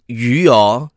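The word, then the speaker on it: yu (鱼): So yu, you know, yu is fish, in Mandarin. In Mandarin, yeah. – In Cantonese, it's called yu, also about the same pronunciation. – In Hokkien, it's hu. – Yeah, hu.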